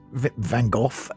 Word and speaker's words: Van Gogh: And it said that you do it with a guttural K-H-O-K-H sound. But then it says, in English, usually pronounced Van Gogh.